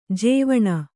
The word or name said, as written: ಜೀವಾಣು